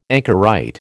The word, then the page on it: anchorite: American English